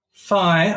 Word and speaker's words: Fai: Look, Fai, which I think is spelled in Japanese F-A-I, as in like the Greek letter, is pronounced Fai and not Fee. – Really? – Really? – Yeah. – Because Fai, the Greek letter, is spelled P-H-I.